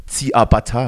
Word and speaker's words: Ciabatta: Und dann, und dann wird so ein schönes Shabada gebacken. – Mm. – Oder Ciabatta heißt das, glaube ich. Richtig ausgesprochen.